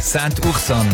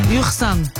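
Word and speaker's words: Saint-Ursanne: Hast du es richtig gesagt? Saint-Ursan. – Ursan. – Ursan. – Ja, Ursan.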